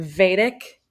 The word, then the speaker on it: Vedic: So I found some other thing about nodes and in the Vedic astrology. Did I say that correctly? – Yes, Vedic astrology.